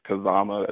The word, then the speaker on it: Kazama: You can follow me at Instagram and Twitter at the Chris Kazama.